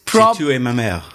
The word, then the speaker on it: J'ai tué ma mère: J'ai tué ma mère. I killed my mother. – Oh, wow. – And it is... – J'ai tué ma mère. That's how. – J'ai tué ma mère.